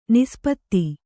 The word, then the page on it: ನಿಸ್ಪತ್ತಿ: nispatti